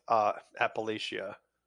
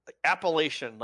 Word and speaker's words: Appalachia: They kept saying Appalachia in that game, and it drove me up a wall. – Appalachia? – It's like, did you notice that, Parker? How they pronounced it? – No, I did not. – Appalachian, like, right? That's how we'd say it, right, Jones? – Yes.